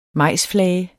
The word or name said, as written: majsflage